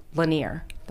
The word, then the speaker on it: Lanier: It's Lake L-I-N-L-A-N-I-E-R. Lanier. That's how you spell it. L-A-N-I-E-R.